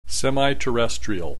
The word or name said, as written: semi-terrestrial